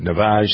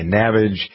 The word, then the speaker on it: Navage: Some people pronounce it Navaj. Some people say Navage. It's N-A-V-A-G-E.